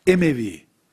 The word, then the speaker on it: Emevi: Ebu Hanife rahmetullahi aleyh Emevilerin, bu kelimenin aslı Umeviliktir arkadaşlar. Bunu telaffuz edersem başka bir kabileyi zikrettiğim gibi anlaşılacak. Türkçe'ye Emevi geçmiş.